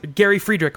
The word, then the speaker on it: Friedrich: in the legal side of things, Gary Friedrich, right? Is that how you say his name? – Yes.